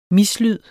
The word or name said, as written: mislyd